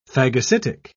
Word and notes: phagocytic: British English

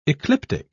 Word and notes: ecliptic: British English

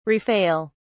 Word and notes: rafale: British English

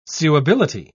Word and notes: suability: British English

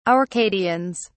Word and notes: Arcadians: British English

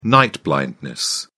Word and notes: night blindness: British English

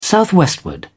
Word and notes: southwestward: British English

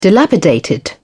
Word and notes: dilapidated: British English

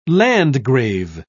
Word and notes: landgrave: British English

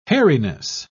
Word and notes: hairiness: British English

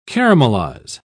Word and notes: caramelise: British English

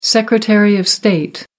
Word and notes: secretary of state: American English

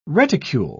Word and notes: reticule: British English

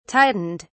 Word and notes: tightened: British English

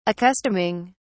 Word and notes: accustoming: British English

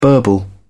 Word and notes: burble: British English

